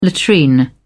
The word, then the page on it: latrine: British English